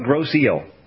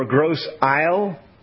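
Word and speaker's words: Grosse Ile: I think they call it Gros Eel. That's it, because it's like it's Gros Island or Gros Isle or Gros Isle, but they call it Gros Eel.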